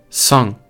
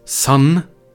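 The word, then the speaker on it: Sang (三): Und auch da wieder die N-Aussprache es ist kein Sun, sondern Sang. Es ist auch nicht das deutsche NG, wie jetzt bei. Was gibt es das Wort? Ja, genau, jetzt fällt mir crazy. Singen. Es ist kein Singen, Sang. Ist nicht genau das. Es ist gleich wie in der französischen Aussprache, wenn man Jean sagt.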